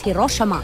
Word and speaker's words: Hiroshima: Or as they say, the proper way to say it in Japan is Hiroshima.